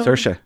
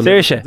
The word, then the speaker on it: Saoirse: There's a Saoirse. – There's a little difference. – It's more on the ah. – Saoirse. – Now you sound like the Americans. Tell me how to say it again. Saoirse.